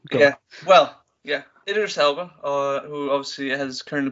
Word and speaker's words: Idris Elba: Uh, is it Idris? Idris Elba? – Yeah. Yes, Idris Elba.